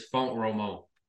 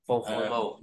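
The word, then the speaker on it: Font-Romeu: It's Font Romo. – How do you say it? – Font Romo. – Font Romo, I think.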